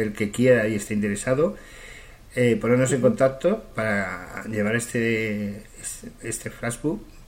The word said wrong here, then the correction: flash mob: no sé si lo he pronunciado mal, corrígeme, No, no, Flash, bueno, sí, es Flash Mob. – Es Flash Mob. – Sí, Mob.